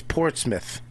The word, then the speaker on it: Portsmouth: It's Portsmouth. – Is that what it is? Yeah, Portsmouth? – It's not phonetically. Portsmouth. Please go to Portsmouth.